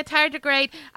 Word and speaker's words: tardigrade: It looks like a tardigrade? Is that how you pronounce it? Yes. That croissant looked full on like a tardigrade.